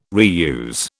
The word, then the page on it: reuse: British English